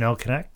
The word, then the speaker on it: El Canek: or he lost to El Sinique in Mexico. – You mean El Canek? – El Canek, yeah. I'm trying too hard with my pronunciations.